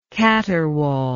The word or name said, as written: caterwauler